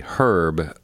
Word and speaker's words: herb: And so just to define our terms, what is the difference between a spice and an herb, or as you say in the UK, herb? We don't pronounce the H.